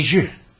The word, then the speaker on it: Xie: And someone might say, we have a guy working for us, his name is Johnny Zhu. Johnny Zhu. Johnny Zhu. His name is spelled X-I-E, his last name.